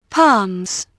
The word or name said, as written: palms